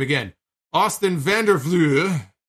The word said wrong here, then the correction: Vanderford: Vanderford. There it is. Vandervleuren. No, it's Austin Vanderford.